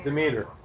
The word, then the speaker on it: Demeter: Okay, the Greeks honored D-E-M-E-T-E-R. – Demeter. – I have such a hard time pronouncing Demeter. Words. Demeter. That's it, Demeter.